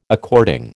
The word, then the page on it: according: American English